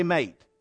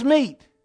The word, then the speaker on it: meet: In fact, it's so misused that people mispronounce it. They talk about Adam had a wife that was his help, and invariably will say mate. But actually, it says meet. It said, and God made Adam a help meet for him. M-E-E-T.